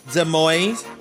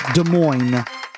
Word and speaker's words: Des Moines: Des Moines. – All right. Des Moines. – Whatever. – You don't pronounce the S.